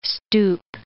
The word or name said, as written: stoop